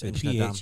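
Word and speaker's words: Inchnadamph: It's spelled D-A-M-P-H, but it's Inchnadam. The P-H is silent, yeah.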